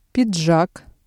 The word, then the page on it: пиджак: Russian